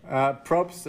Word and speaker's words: Probst: How do you say it? – Probst is fine, I guess.